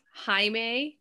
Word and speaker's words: Jaime: Jaime, because my name is spelled J-A-I-M-E, which in usually Mexican, I think some Spanish people too, it's a male name.